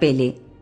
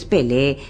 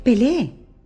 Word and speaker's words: Pelé: Pele. Al principio quiso ser simpática y corregir la pronunciación. Es Pelé, Pelé.